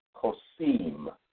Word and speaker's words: Kosem: That word is Kosem, and that's pronounced, that is spelled K-O-S-E-M, Kosem.